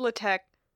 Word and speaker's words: LaTeX: Because it's called LaTeX, but it's spelled like latex, and it's spelled all caps, but some letters are higher than others, so it makes a zigzag for no reason at all.